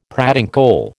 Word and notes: pratincole: American English

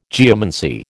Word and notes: geomancy: American English

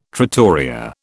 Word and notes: trattoria: British English

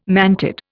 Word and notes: mantid: Indian

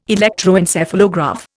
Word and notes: electroencephalograph: Indian

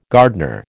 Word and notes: gardener: American English

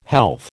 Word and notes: health: American English

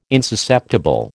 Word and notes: insusceptible: American English

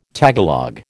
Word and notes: Tagalog: American English

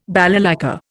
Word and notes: balalaika: Indian

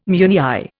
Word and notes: meunière: Indian

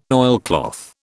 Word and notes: oilcloth: British English

